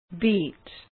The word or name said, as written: beet